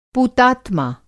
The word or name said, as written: पूतात्मा